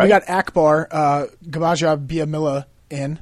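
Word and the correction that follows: Gbaja-Biamila: By the way, that's it's a silent G. – It's silent. – It's Akbar Baja Biamila.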